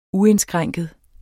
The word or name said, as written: uindskrænket